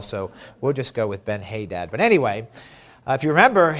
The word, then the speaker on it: Ben-Hadad: Now, if you'll remember, when chapter 20 began, the king of Syria, Ben-Hadad, we're going to go with Ben-Hadad tonight, amen? – Amen? – And that's how we'll pronounce it. You all convinced me of that. It's actually the right thing, right way to say it, I believe.